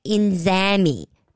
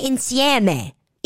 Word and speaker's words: insieme: So she says, sempre inzami. It's sempre insieme.